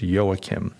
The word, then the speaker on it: Joachim: His name is pronounced Joachim. It's spelled J-O-A-C-H-I-M. J-O-A-C-H-I-M.